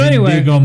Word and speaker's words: Indigo: I-N-D-I-G-O.